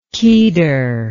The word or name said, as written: kedar